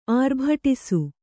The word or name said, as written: ಆರ್ಭಡಿಸು